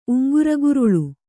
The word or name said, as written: ಉಂಗುರಗುರುಳ